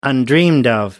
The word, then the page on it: undreamed of: American English